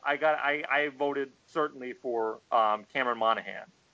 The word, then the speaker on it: Monaghan: First of all, for Choice TV Villain, two DC nominees here, Cameron Monaghan from Gotham and Odette Annable from Supergirl. – I'm pretty sure that's not how you pronounce it, but we'll go with it. – Oh, right. It's Monaghan.